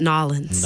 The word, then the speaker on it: New Orleans: Oh, so you we actually pronounce it Nolens.